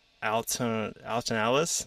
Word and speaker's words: Alton Ellis: So your guys' name is from the Alton Ellis.